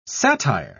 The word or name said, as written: satire